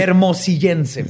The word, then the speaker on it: hermosillense: ¿Pero te dijiste hermollicense? – Se dice así. Hermosillense, perdón. – No, es que me quedé trabado ahí. – hermosillense Es hermosillense.